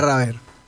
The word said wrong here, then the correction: Ravier: Adrián también, Ravier. – Adrián Ravier, lo dije bien.